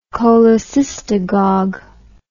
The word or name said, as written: cholecystagogue